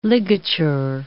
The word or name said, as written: ligature